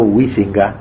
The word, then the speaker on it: Huizinga: Hay un pensador holandés, protestante creo, llamado Huizinga, Huizinga se dice, pero nadie lo pronuncia así, Juan Huizinga, que escribió un libro que se llama así, Homo Ludens, el hombre que juega.